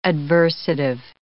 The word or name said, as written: adversative